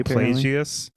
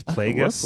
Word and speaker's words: Plagueis: I was saying Plagueis. It's Plagueis. – Plagueis. – Huh. It's Plagueis, apparently.